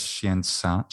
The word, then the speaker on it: Scientia: Ignis Scientia, Ignis Scientia in ecclesiastical pronunciation.